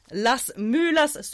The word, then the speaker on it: Las Mulas: Först och främst, det här avsnittet och vårt ettårskalas presenteras i samarbete med Constant Reader, The English Bookshop, Las Mulas Sauvignon Blanc. Jag är inte helt säker här på det spanska uttalet. Det var okej, säger.